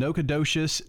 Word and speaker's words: Nacogdoches: Davy Crockett arrives in Nacogdoches, Texas, to aid the revolution.